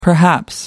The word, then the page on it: perhaps: American English